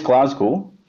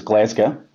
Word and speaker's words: Glasgow: Um, it is Glasgow, but uh, it's locally referred to as Glasgow. And I suppose that comes from the original Celtic pronunciation, which was Glasgow, which means dear green place which is uh, what the Vikings thought of it when they turned up.